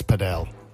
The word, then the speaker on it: padel: Well, if you like squash a little bit and you also like tennis, then paddle. – Yeah, but sorry, can I just stop you? It's padel. – What? – Many people pronounce it padel.